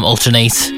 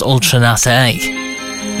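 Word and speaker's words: Ultra Naté: I used to call him Alternate, but it's pronounced Ultranate.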